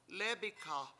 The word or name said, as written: lEEbikha